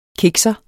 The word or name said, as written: kikser